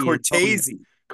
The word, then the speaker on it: Cortese: Pete Cortez, I'll never say his name right, Mike D. – Cortese. I always thought it was Cortez. – Yeah, but I know who he is. – It's like Scorsese.